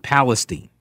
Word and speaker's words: Palestine: Before it gets to East, and they pronounce it Palestine.